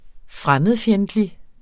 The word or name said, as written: fremmedfjendtlig